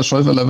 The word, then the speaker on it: Schäuferle: Ja, das ist vollkommen richtig, aufs Schäuferler. Es weiß keiner, wie man es schreibt, aber Schäuferler wird es ausgesprochen.